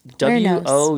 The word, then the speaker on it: Wuornos: It's not like a Hispanic name. I don't know. It's Wuornos.